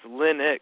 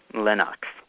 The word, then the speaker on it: Linux: Pronouncing Linux like Linux. – No, it's Linux. – It's spelled here. He wrote it LIH-NUKS. Linux.